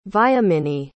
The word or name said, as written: Vyomini